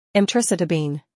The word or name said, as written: Emtricitabine